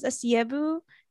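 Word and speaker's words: Asiegbu: Okay, my last name's Asiebu. The G is silent, so there is no G.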